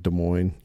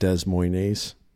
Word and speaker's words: Des Moines: The S is silent. It's kind of like Des Moines, you know, right. – Des Moines.